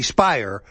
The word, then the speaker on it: Speier: Perhaps this is why Jackie Speier rhymes with liar.